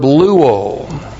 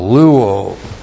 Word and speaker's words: luo: And then the verb luo. L-U long O, luo.